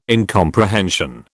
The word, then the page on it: incomprehension: British English